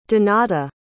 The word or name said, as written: donata